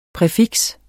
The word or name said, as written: præfiks